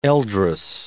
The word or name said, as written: eldress